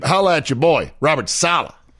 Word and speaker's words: Saleh: Wait, is that how you pronounced? I thought it was Saleh. – Saleh. It is. It's Saleh.